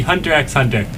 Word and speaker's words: Hunter x Hunter: My favorite show would be Hunter x Hunter. – Awesome. – You don't pronounce the X.